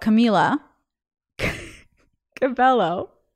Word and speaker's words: Camila Cabello: So, but anyway, she wrote me something about Camila Cabello, and I knew I was going to mispronounce it.